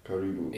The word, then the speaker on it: karibu: You gotta say karibu in Swahili. – What do you say? – Welcome, karibu. – Karibu. – Yeah. – Karibu.